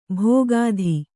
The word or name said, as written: ಭೋಗಾಧಿ